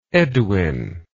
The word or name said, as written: edwin